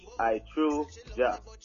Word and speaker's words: I Tru Jah: So my name I Tru Jah. Okay, so first and foremost, it's spelled I space T-R-U space J-A-H. I space T-R-U space J-A-H. I Tru Jah.